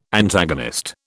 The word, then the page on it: Antagonist: British English